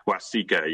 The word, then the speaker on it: Waseca: There's also one in, I might mispronounce this, Waseca? – Waseca, yeah.